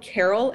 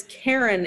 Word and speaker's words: Caron: Like, so it's from a Canadian company called, for a long time I thought they were called Carol and Doucette, but it's Karen and Doucette, but it's Karen spelled like C-A-R-O-N.